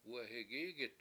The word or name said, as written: wehigIIgit